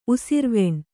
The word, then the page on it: ಉಸಿರ್ವೆಣ್: usirveṇ